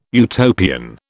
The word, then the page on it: Utopian: British English